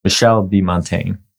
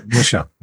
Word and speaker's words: Michel de Montaigne: Today, we're going to start with Michel de Montaigne.